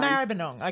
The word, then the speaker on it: Maribyrnong: Maribyrnong.